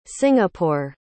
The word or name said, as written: singapore